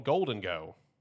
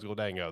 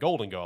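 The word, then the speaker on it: Gholdengo: which I just found out is called Golden Go. I didn't know that. I thought it was Goldango this entire time. That's how I've heard everyone pronounce it. But they said it in the trailer. It's like Golden Go.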